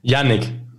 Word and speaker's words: Jinnik: Jinnik.